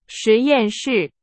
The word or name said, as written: 实验室